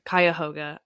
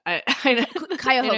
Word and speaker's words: Cuyahoga: Cuyahoga. – Cuyahoga, you know, you said, all good, all good.